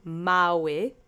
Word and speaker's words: mały: So let's say mały together three times. Accent is on the first syllable.